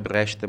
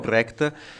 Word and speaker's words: Brecht: Sentite le canzoni di Kurt Weill, ad esempio, quelle faceva i spettacoli con Brecht, che si pronuncia Brecht, ma se dico Brecht non lo capisce nessuno, diciamo Brecht, se li sentite, sono canzoni che richiedono una interpretazione, cioè un tono diverso, il calarsi nei panni di un personaggio.